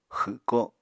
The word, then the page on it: xwko: Apatani